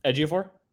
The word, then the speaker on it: Ejiofor: The first time I ever saw Chiwetel Ejiofor? – Yes. That's how you pronounce it.